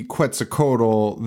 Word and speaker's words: Quetzalcoatl: Is that how you say it? Quetzalcoatl. – You nailed it. That's how you say it. Yeah. – So instead of calling the movie Quetzalcoatl, they called it Q, which makes a lot of sense.